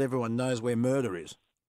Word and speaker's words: Mernda: Mernda. It's M-E-R-N-D-A.